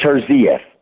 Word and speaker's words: Terzieff: Is that how you pronounce her last name? – Yeah. Juliet Terzieff. The stress on the second syllable.